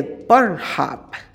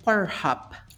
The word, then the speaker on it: Pornhub: Nada, pues vamos a hablar de Pornhub. Bueno, lo primero, ¿cómo diríais que se pronuncia? Sería Pornhub.